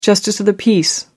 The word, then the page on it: justice of the peace: American English